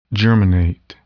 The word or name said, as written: germinate